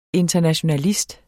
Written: internationalist

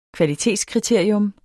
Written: kvalitetskriterium

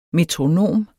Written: metronom

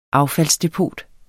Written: AFFALDSDEPOTER

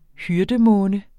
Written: hyrdemåne

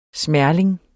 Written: smerling

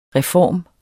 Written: reform

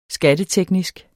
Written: SKATTETEKNISKE

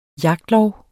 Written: jagtlov

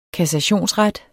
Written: kassationsret

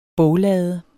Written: boglade

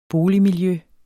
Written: boligmiljø

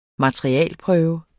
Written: materialeprøve

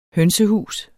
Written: hønsehus

